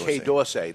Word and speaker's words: Quai D’Orsay: Quai D’Orsay.